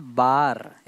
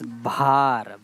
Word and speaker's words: भार: It's not bar, it's bhar.